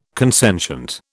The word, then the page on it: consentient: British English